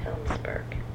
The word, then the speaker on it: Helmsburg: Helmsburg. – How do you spell that? – H-E-L-M-S-B-U-R-G.